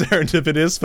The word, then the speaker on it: serendipitous: Yeah, it is pronounced serendipitous, but it is a T O U S.